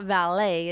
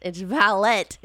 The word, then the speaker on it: valet: and yes, we're saying it correctly. It's not valet; it's valet in the horse racing industry, of course.